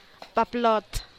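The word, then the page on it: paploot: Teotitlán del Valle Zapotec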